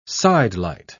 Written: sidelight

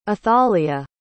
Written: athalia

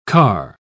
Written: car

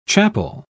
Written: chapel

